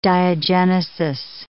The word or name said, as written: diagenesis